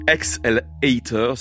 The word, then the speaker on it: XL8R: Et selon son témoignage, dont j'ai pris connaissance sur un site très intéressant qui s'appelle XLHater, ça s'écrit XL8R.